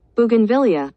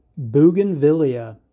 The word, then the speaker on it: Bougainvillea: Bougainvillea. Bougainvillea. Bougainvillea.